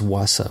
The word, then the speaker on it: Vasa: V-A-S-A, Vasa, or I think it's actually pronounced Wasa.